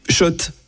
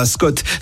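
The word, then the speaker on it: Schott: Oui, Shot. Et pas Scott, ça fait des années qu'on prononce mal la marque.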